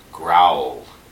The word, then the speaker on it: Graal: And before you know it, we're introduced to our villain, Lord Growl. Okay? – Well, spelled G-R-A-A-L, but pronounced growl, as if, you know, the animal growled, right?